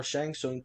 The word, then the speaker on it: Shang Tsung: forcing the tournament Shang Tsung, thank you. – Don't pronounce the T.